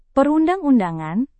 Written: perundang-undangan

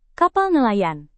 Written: kapal nelayan

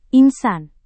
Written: insan

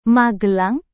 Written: magelang

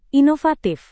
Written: inovatif

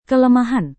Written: kelemahan

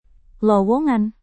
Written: lowongan

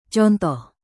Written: contoh

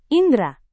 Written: indra

